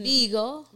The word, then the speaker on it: Bigo: B-I-G-O. Yeah. I saw its name. How you pronounce that? – It's Bigo, right? Not Bigo. – Bigo. – Big O. – I don't know. – Okay, right. Big O. That would make sense.